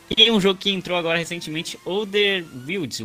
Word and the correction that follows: Outer Wilds: Wilds, não sei como é que se pronuncia. Outer, Wilds. – Isso, Alter Wilds. Outer Wilds, exatamente. Joga esse jogo.